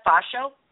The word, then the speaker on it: Pho Show: Now, the other one, how did you pronounce that, Fasho? – Yes, it's funny. It's actually P-H-O and then S-H-O-W. So it's a cute little fun play.